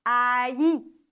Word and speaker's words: allí: Doppel-L wird wie ein J ausgesprochen und dadurch wird das Wort ein bisschen schwierig auszusprechen, aber es heißt allí.